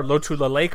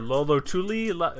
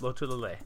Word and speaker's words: Lotulalei: Hey, I'm one of the few people who can say Star Lotulalei correctly. – That's true. – I hear Lolotuli. No, it's Lotulalei.